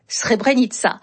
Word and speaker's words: Srebrenica: L'armée de libération du Kosovo, qui s'écrit U-C-K. Avec un signe diacritique en dessous du C, se prononçait Ucheka. Alors que la ville de Srebrenica, qui s'écrit bien avec un C, mais avec le signe diacritique au-dessus, se prononce Srebrenica. Signe en dessous du C, ça donne Tché. Signe au-dessus du C, ça donne Tse.